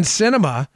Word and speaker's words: Sinema: Now we find out, Joe, that Kyrsten Sinema, who is another phony who seems to love attacking the citizens of Arizona.